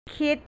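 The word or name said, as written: คิด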